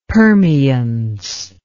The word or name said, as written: permeance